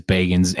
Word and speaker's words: Bagans: Yeah, it is Bagans. Henry Zebrowski fucking pronounces it incorrectly.